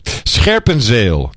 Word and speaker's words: Scherpenzeel: Scherpenzeel. – Yeah, very good. Scherpenzeel. Try that again. – Scherpenzeel. – Yes, excellent.